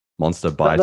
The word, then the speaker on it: Monsterbitar: Monster Bitar. – That's right. it's Swedish. It's pronounced Monster Bitar. It's essentially two words. One is the word monster, and the other is parts.